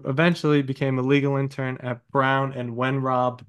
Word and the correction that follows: Weinrob: Did I say that right? – Weinrob, and if I don't correct you, then somebody will text me and I'll get scolded.